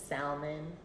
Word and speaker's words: salmon: Because in Spanish you say salmón. – Of course. – And you pronounce the L.